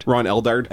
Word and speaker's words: Eldard: No, but you, I think it was your number two, was that scene between Robert Duvall and Ron Eddard? – Ron Eldard. Which I think I'm pronouncing that A very hard. – Okay. – Probably just like Ron Eldard.